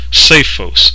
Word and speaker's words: psephos: The English word stone is here translated from the Greek word spelled P-S-E-P-H-O-S, and it's pronounced Sephos.